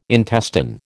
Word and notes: intestine: American English

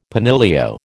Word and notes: penillio: American English

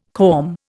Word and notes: corm: Indian

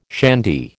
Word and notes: shandy: American English

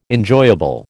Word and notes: enjoyable: American English

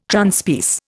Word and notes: Transpierce: Indian

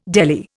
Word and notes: deli: Indian

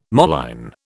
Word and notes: moline: British English

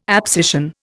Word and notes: abscission: Indian

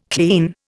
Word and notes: clean: Indian

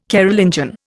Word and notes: Carolingian: Indian

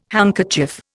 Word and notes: handkerchief: Indian

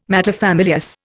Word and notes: materfamilias: Indian

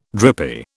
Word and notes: drippy: British English

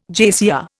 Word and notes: JCR: Indian

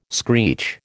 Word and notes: screech: American English

